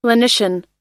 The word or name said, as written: lenition